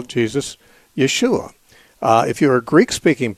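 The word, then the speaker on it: Jesús: If you're a Spanish-speaking person, you'd say Jesus, which is spelled the same as Jesus, but pronounced differently, because in Spanish they pronounce the J as an H.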